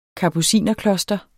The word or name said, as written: kapucinerkloster